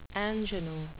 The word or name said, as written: ingenue